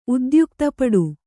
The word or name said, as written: ಉದ್ಯುಕ್ತಪಡು